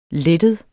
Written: lettet

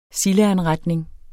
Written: sildeanretning

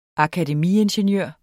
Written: akademiingeniør

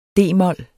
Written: d-mol

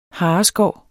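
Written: HARESKÅRENE

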